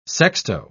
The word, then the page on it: sexto: British English